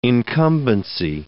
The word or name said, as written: incumbency